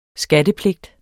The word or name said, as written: skattepligt